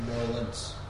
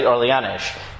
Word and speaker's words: Orléans: Right, you see, in Chos is often written with a shin, and like some people pronounce it very incorrectly, Orleanish. It is not Orleanish. This is what we would call in English Orleans, right? In French would be like Orléans.